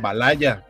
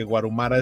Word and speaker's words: Valhalla: Hay un capítulo en el que le dicen Valaya, porque técnicamente podría pronunciarse así. En otro episodio el narrador lo dice como Guarumara, porque Guarumara es lo más cercano a la pronunciación en japonés de Valhalla. Entonces es Palacio Guarumara, Guarujara, Valaya, y creo que nunca le dicen Valhalla.